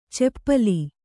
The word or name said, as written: ಚೆಪ್ಪಲಿ